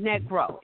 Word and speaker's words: negro: Because the word Negro, and that's how they pronounce it, Negro, N-E-G-R-O.